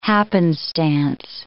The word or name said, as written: happenstance